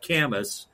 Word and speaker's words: Camus: So Alan Watts, and then also I'm a big fan of Albert Camus, C-A-M-U-S. It's a trick name. It looks like Camus, but it's pronounced Camus, the French existentialist writer.